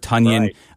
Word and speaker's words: Tonyan: But Robert Tonyan rhymes with Funyan, we found out this week. Robert Tonyan looks like he's going to be available for the Packers, so you can probably feel safe.